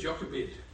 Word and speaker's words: Jochebed: And he married Yochebed. If you look at how they pronounce it in the Hebrew, it's Jochebed.